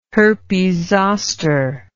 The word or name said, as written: herpes zoster